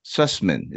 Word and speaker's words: Sussman: And co-wrote 4 episodes with Mike Sussman. Is that I'm pronouncing his name right? S-U-S-S-M? – Absolutely.